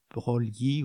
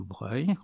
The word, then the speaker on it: Broglie: Autrement, bien, au niveau scientifique, le futur prix Nobel Louis de Broglie ou Broglie, on peut prononcer des deux façons, met au point la théorie des quantas, ce qui donne une image très dynamique de la recherche scientifique française.